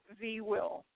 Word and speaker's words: VWIL: So VWIL, the cadets pronounce as VWIL, so the V and then will. And then they've got all kinds of little, you know, VWIL, SHEWIL, I will, you will, she will, you know, that whole thing. So that's how they pronounce it, V will.